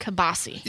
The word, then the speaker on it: kielbasa: Or as your mom would pronounce it, kibasi. – Yeah, kibasi. That's how we do it in Maine. That's kibasi.